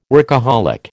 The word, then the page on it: workaholic: American English